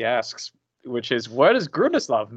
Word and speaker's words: Grundislav: So you are actually uh, from uh, I don't know how to pronounce it. Gundaslav Games. – Gundaslav. – Grundislav. – Grundislav Games. Okay.